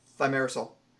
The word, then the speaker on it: Thimerosal: I can pronounce it for you if you want me to. – Thimerosal? – Thimerosal. – Thank you. – Thimerosal.